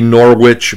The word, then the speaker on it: Norwich: You can Google it, Ann Norrich, N-O-R-W-I-C-H. It could be Norwich, but I'm assuming she pronounces it the New England way, which is Norwich, like the terrier.